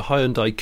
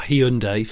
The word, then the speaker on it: Hyundai: and I got overtaken by a, um, it was a Hyundai Coupe, or Hyundai for Amer- American listeners.